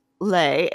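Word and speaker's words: Lez: Then there's Les Represent, that's L-E-Z Represent. I'm not mispronouncing the French Les, L-E-S, it's Les.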